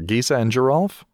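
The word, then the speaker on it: Gisa and Geralf: How do you pronounce that? Is it Geesa and Giralf or Geesa and Giralf? – I would go for the latter. – I believe it's Geesa and Giralf. – So it's the soft and hard G, and respectively.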